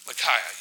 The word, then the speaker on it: Micaiah: Micaiah, right? Micaiah.